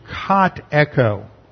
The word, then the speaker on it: katecho: But the Greek word here is katecho. And katecho is spelled K-A-T-E-C-H-O.